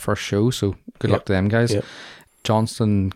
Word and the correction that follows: Johnstown: Castle Wexford, am I pronouncing that rightly? Johnstown, Johnston? – Johnstown, I would say. Johnstown Castle in Wexford.